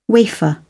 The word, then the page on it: wafer: British English